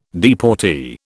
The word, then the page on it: deportee: British English